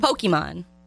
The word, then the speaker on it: Pokemon: I think the poll question should be, is it Pokemon or Pokemon? – Pokemon. – Yeah, it's definitely a, what is that, a hard E? What is that called? – A hard E? – The long E. Thank you.